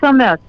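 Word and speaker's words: Amess: Is it Ames? – I think it's Ames. Yes, okay.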